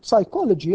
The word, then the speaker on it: psychology: At the time, psychology in the popular imagination was an exotic species for a variety of reasons, not least its silent P.